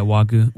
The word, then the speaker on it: Wagyu: Yeah, Wagyu.